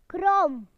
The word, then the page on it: wiggly: British English